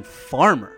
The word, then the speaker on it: Farmar: I went to high school with the dude, and back then, he went with the pronunciation Farmer.